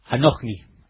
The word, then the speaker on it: Anohni: Anohni. A-N-O-H-N-I. Anohni.